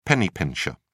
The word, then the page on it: penny-pincher: British English